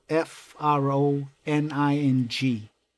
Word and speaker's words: Froning: It's, I think it's spelled F R O N I N G.